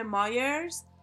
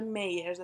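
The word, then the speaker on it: Myers: اصلا میگن اگه اونجوری که داره توی تبلیغ میگه باید بشه میرز اصلا نباید مایر تلفظ بشه ولی این داره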